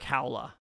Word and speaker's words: Haula: Oh, Eric Haula.